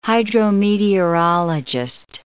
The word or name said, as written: hydrometeorologist